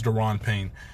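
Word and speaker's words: Da'Ron Payne: Darren Payne, yes, I was thinking how to say his name. I think it's Daron Payne.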